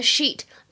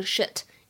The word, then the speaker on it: sheet: 在英语当中呢，如果碰到 a clean sheet 或者呢 a clean slate，这里的 sheet 大家千万要注意，不要给我读成 shit，意思完全变了啊，所以发音是非常重要的。